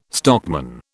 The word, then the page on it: stockman: British English